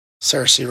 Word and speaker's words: Saoirse: Saoirse Ronan.